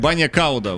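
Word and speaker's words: bagna cauda: У меня банья кауда. – И клакер. – Ой. – Извините, у меня ударение банья кауда, вот так.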